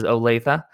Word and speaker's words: Olathe: This is from Rob Martin, who also gave us some help on the pronunciation of Olathe, Kansas, which is, yes, it was Olathe.